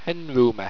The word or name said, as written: henruma